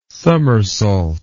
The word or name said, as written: summersault